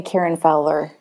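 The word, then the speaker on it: Karyn Fowler: I think I'm the only Karen Fowler on LinkedIn. My first name is spelled K-A-R-Y-N. And then last name is F as in Frank, O-W-L-E-R.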